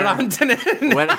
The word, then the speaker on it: Rantanen: And was it Rantanen with the winner? – Rantanen. – that's the appropriate pronunciation. – Ask Pierre McGuire. – We're saying it correctly.